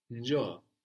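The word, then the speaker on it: njoo: It's njo. So that's N-J-O-O, njo. – Njo. – So we start just with the N sound without any vowel before. No, we don't say njo, but njo. – Njo. – Good. And the accent is on the end, no?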